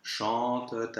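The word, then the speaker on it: chantent: Now, here, chante is a verb in the third person of the plural, so that ENT ending is actually just a schwa with a silent T, which means that we can have the liaison after.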